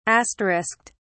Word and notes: asterisked: British English

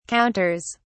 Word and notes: counters: British English

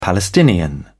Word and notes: palestinian: British English

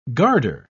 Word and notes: guarder: British English

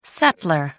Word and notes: settlor: American English

